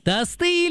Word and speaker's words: тосты: Тосты или тосты? – Тосты. – Да! Да! Тосты! Ну тосты же! Так что ударение в данном слове всегда и независимо от его значения падает только на первый слог.